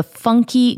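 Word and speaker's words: Pfunky: There is a delightful cafe here, if you've not been and you live here in Nashville, called the Funky Griddle, P-F, funky, with a silent P.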